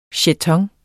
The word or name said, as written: Jetoner